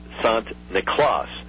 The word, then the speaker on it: Sant Niklaas: And it was the Dutch that brought those traditions to America, and the Dutch pronounced St. Nicholas Sant Niklaas. And so if you say Sant Niklaas a couple times, Santa Claus, Santa Claus, Santa Claus, that's where you get Santa Claus from.